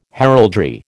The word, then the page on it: heraldry: American English